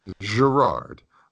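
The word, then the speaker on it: Gerard Gallant: I think the head coach, Jared Gallant, I don't even know if I said it right. – Yeah, Jared Gallant. You didn't add like the French side of it, but you're close enough. – Gerard.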